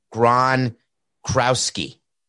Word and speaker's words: Gronkowski: I think I'm missing three syllables. Rob Gronkowski. Being his tight, Rob Gronkowski.